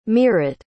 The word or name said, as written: meerut